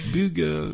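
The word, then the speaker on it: Bugge: C'est Jean-Christian Budge Wesseltoft. – C'est prononcé n'importe comment. Bug. – Bug, oui. Voilà.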